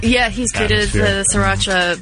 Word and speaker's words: Sriracha: Oh, I said it wrong. I always say it wrong. I always say Shiraka, but I don't. I guess there's not even an H in there.